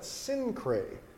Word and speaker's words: Cenchrea: So what he does, he goes to a place called Cenchrea. That's how you pronounce that, Cenchrea. If you try to pronounce it like phonetically, how it's spelled, you end up with some people think you're speaking in tongues.